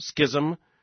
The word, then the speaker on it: schism: By the way, every time that word comes up, I hear from listeners who say it's supposed to be pronounced this way or that way. Actually, the dictionary says it's schism, schism, you name it, whatever it is. And the most common pronunciation when it comes to a denomination or a church is schism.